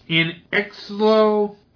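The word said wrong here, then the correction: Xolo Maridueña: Actually, that's Xolo Maraduena.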